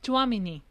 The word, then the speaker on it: Tchouaméni: no sé si me corrige si lo digo mal, ¿Tchouaméni? – Tchouaméni.